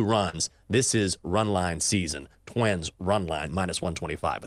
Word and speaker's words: SZN: S-Z-N. Do you pronounce it that way or do you still say season? Or is it season? – Season.